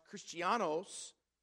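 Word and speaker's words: Christianos (Χριστιανός): The word Christians is from a word that is pronounced Christianos, which is simply that, a follower of Jesus Christ.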